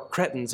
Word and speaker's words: Cretans: You know, Cretans had a really, or Cretans, if you take Mike Wazowski's advice on the pronunciation thereof, had a bad reputation.